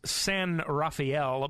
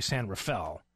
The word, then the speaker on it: San Rafael: Now, it says San Rafael, but I was told by somebody in California it's actually San Rafael, that that's the way they pronounce it down there.